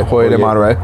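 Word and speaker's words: Hoyo de Monterrey: Hoyer de Monterey, yeah.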